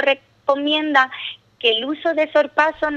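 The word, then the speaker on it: sorpasso: Dice, aconseja en cuanto a la pronunciación, sorpaso, con acento en la A, y en la ortografía exigen que por ser un extranjerismo debe escribirse con cursiva.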